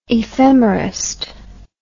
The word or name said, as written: ephemerist